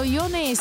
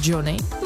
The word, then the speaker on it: Jonny: A jestli nás teď poslouchá někdo, kdo se diví, proč neříkám Johnny, no tak tady je vysvětlení. Jeho umělecké jméno se píše Jonny, ale nevyslovuje se anglicky jako Johnny, ale jednoduše česky jako Jony.